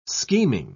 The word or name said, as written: scheming